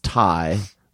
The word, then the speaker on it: Thai: He would deliberately mispronounce Thai when they get Thai food. He would call it thigh food.